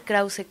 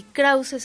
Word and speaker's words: Krauze: Pero Ethel Krause K, Ethel se escribe E-T-H-E-L y Krause se escribe con K de kilo, K-R-A-U-Z-E.